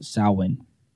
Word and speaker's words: Samhain: So it came from the ancient Celtic festival, Samhain. Samhain. – Samhain. Hello, Samhain. But that sounds kind of like Asian or something. – It's spelled S-A-M-H-I-N, but it's pronounced Samhain. – Like S-O-W-I-N.